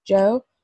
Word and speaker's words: Djo: Okay, so his real name is Joe Keery, but he is going under the name, I think it's Joe, but it's like D J O, but maybe it's yeah. pronounced Joe.